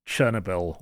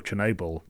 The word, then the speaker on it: Chernobyl: My number one show was Chernobyl, or Chernobyl, depending on how you want to pronounce it, which is HBO Sky Atlantic.